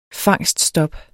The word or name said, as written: fangststop